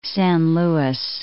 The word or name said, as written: San Luis